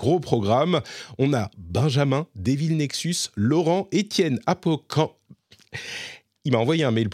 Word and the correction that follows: Acop: Try again. Étienne Acop Voilà, c'est pas si dur pourtant.